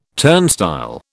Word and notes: turnstile: British English

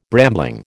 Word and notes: brambling: American English

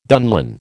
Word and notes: dunlin: American English

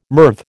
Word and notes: MIRV: American English

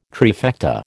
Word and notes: trifecta: American English

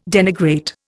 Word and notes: denigrate: Indian

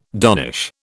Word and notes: donnish: British English

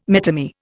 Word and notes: metamere: Indian

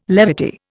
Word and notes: levity: Indian

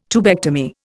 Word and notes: tubectomy: Indian

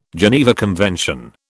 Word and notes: Geneva Convention: British English

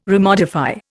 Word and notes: remodify: Indian

